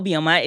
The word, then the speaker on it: Obioma: Okay, so Beulah Obioma is here with me today, and I'm so excited.